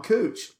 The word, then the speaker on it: Couch: Okay, so apparently in Portland, that is not how you pronounce it. And in Portland, couch, that word is pronounced cooch.